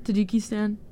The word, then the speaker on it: Tajikistan: So wait, so how do you say it? Is. It's Tajikistan.